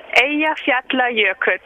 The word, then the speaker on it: Eyjafjallajökull: No, il nome è Eyjafjallajökull.